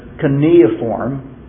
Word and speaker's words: cuneiform: Cuneiform.